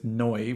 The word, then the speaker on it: Neu: and especially with Neu, which I only just discovered that's how you pronounce it, spelled N E U.